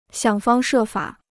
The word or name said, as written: 想方设法